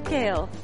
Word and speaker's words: Gail: R-O-B. – And your name? – Gail. – Gail, can you spell that? – G-A-I-L.